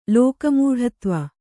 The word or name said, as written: ಲೋಕಮೂಢತ್ವ